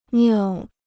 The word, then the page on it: 尿: niào